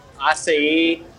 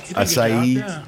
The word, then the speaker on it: acai: How is that pronounced? The A-C-A-I berry? – Acai. – Acai. – Yeah. – I would say acai. – Yeah, me too.